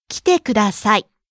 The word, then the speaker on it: 来てください: 来てください is a phrase that means please come. So 来て has a short e vowel.